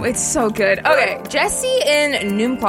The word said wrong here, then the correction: Enumclaw: I think that's how you pronounce that. I hope and I pray. – Enumclaw, yes. – Enumclaw.